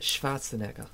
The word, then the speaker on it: Schwarzenegger: It's pronounced Schwarzenegger.